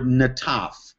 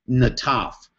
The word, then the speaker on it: nataph: and that's the word Nataf. You spell that N-A-T-A-P-H, Nataf, and that word means to drop.